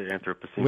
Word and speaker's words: Anthropocene: we're living in the Anthropocene epoch. Did I say it right? – Yeah, you know, I've heard it Anthropocene and Anthropocene. Either way, I'd say Anthropocene.